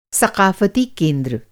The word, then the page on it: सक़ाफ़ती: ثقافتی